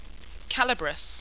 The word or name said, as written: calabrus